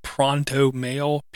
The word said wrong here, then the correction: Proton Mail: P-R-O-N-T-O Mail. – Proton. No, Proton Mail. – Proton Mail. – Proton Mail. Sorry.